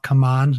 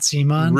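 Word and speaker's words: CMON: Now I've been pronouncing it wrong forever. They issued a statement saying it's called Come On, not Simon.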